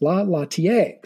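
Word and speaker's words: La Laitière: I am told is pronounced La Lattier.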